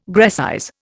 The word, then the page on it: Grecize: Indian